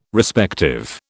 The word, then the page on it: Respective: British English